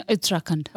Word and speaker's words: Uttarakhand: No, Uttarakhand.